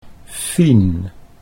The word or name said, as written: Fien